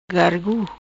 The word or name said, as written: Gargu